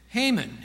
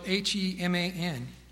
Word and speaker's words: Heman: David also appointed Haman. It's spelled H-E-M-A-N. Looks like He-Man, but that sounds kind of weird. So I say Haman. That may sound weird too. H-E-M-A-N. Haman.